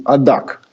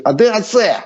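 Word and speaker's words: ADAC: Всегда есть несколько немцев, например, которые мы привыкли произносить клуб «АДАК». Они говорят: «АДАЦ! Вы что там себе позволяете?» Мы говорим: «БМВ».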